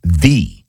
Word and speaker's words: ye: The word ye, as in like ye old shop, is actually pronounced thee. In medieval English, people used a Y to abbreviate the TH.